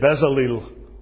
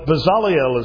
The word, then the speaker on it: Bezaleel: Most people say Bezaleel. That's okay. If you don't mind being wrong, that's fine. But Bezaleel is how you say it.